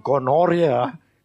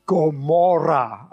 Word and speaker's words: Gomorrah: You know Gomorrah? Not gonorrhea. It's Gomorrah. All right? Get the pronunciation correct.